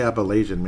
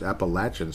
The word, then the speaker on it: Appalachian: And that is how you say it, apparently. I used to say Appalachian many years ago. It's Appalachian State, which sounds kind of cool.